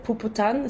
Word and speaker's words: Puputan: And I also chose Paolo Self-Sacrifice Ritual because I was interested in this, is it Puputan? Is that how I pronounce it? – Yes.